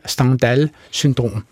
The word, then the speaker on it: Stendhal-syndrom: Altså, kan I belyse det her Stendhal-syndrom, hvis jeg ellers udtaler det rigtigt? Det er udtalt rigtigt.